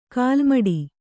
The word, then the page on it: ಕಾಲುಮೆಟ್ಟು: kālumeṭṭu